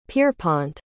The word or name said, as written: pierrepont